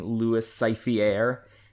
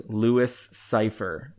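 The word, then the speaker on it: Louis Cyphre: Mickey Rourke is insisting on pronouncing his name Louis Cyphiere, and then De Niro keeps going, no, it's Louis Cypher.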